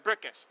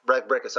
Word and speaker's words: Bricusse: The person that wrote the lyrics for "Can You Read My Mind" was a lady named Leslie Bricusse. I guess I would pronounce it. – Bricusse. They actually mentioned on the commentary. Leslie Bricusse. – Oh, Bricusse.